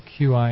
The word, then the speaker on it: qi: So the terms that he translated as energy, the Chinese word is qi, and spelled Q-I, and pronounced qi as in cheese.